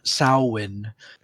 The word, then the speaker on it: Samhain: Yeah, Samhain. – Some satanic. Or Samhain. Is how it's pronounced.